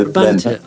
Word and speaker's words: Brabantia: Is it Barbantia or Brabantia? – Yeah, Brabantia.